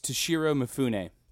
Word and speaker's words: Toshiro Mifune: But his name is Toshiro Mifune, and Toshiro Mifune was basically, you know how Martin Scorsese has done like 5 films with Leonardo DiCaprio? – Yeah. – Toshiro Mifune is Akira Kurosawa's DiCaprio.